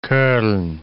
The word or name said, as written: Koln